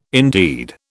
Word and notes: indeed: British English

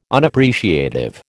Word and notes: Unappreciative: American English

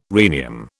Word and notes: rhenium: British English

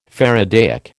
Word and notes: faradaic: American English

